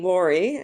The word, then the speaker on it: Lori: Because my name, Lori,